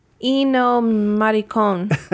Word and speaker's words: Ennio Morricone: Ennio Morricone.